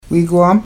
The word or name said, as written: wiigwaam